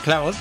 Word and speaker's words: Claude: Claude, lo he dicho bien, Cloud, pues sí, sí, los de Cloud, los de ChatGPT, los de...